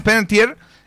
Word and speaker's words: Panettiere: Entre los famosos que están de cumpleaños hoy está esta actriz estadounidense, fallecida lamentablemente hace algunos días, Hayden Panettiere. Lo dije bien, ¿cierto? Sí, Hayden Panettiere, de la cual vi muchas películas, sobre todo cuando yo era más joven.